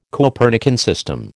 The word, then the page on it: Copernican system: American English